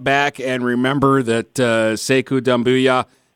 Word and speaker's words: Doumbouya: It's Booyah like Stuart Scott, but it's Ya instead of Boo that's emphasized. So good job on that. – Thank you. – Sekou Doumbouya.